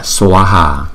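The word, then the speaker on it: svāhā: Hai chữ A này có dấu gạch ngang trên đầu cho nên chúng ta đọc rõ ra là swaha, swaha, swaha. Chữ S và chữ V đi chung với nhau chúng ta đọc là swa, swa, swaha, swaha, swaha.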